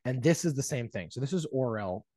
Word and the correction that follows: Orlen: or or Orel Orlen. I can never pronounce the name. It's an oil company. It's the chief sponsor, Orlen. Yeah, or- Orlen. Orlen. Orlen. Orlen. Thank you.